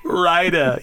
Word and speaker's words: Flo Rida: I'm just saying that some Flo Rida and T-Pain is not too bad. I'm just saying. – Rida.